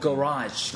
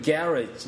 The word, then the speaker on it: garage: 뭐 진짜 그렇게 막 뭐해서 뭐 garage 같은 경우도 영국에서는 garage 그러니까 강세가 garage.